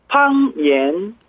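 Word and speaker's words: 팡옌 (方言): 방언이라는 한자를 그대로 써가지고요. 일성하고 이성을 씁니다. 그래서 팡옌, 팡옌, 사투리 방언이었습니다.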